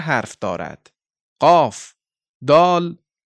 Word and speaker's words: دال: و دال این طور تلفظ می شود. دال.